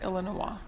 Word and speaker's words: Illinois: I think it's pronounced Illinois.